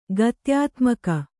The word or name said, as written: ಗತ್ಯಾತ್ಮಕ